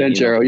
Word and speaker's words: Banchero: How do you say his last name? Paolo has played it. – Banchero, you got it.